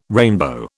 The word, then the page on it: rainbow: British English